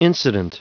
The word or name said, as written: incident